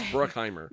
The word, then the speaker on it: Bruckheimer: There was no I want to add that extra syllable. – There is no E-N in there. Anyway, it's just Bruckheimer.